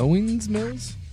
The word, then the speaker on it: Owings Mills: More specifically, Owings Mills, Maryland. – Owings Mills? – I think is how you pronounce it. I think that's right.